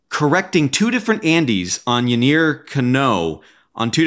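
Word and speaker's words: Yennier Cano: So it's actually pronounced Yainer Cano.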